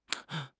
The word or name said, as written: 蹬